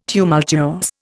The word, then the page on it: tumultuous: Indian